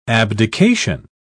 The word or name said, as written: abdication